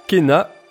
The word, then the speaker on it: Kena: Alors, Kena euh, Bridges of Spirit.